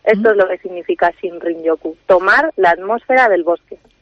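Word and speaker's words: Shinrin-yoku: Su nombre japonés es Shinrin Yoku, que seguramente lo he dicho fatal. – No, está muy bien, lo has pronunciado muy bien. Shinrin Yoku, no te creas que yo tampoco lo pronuncio mucho mejor.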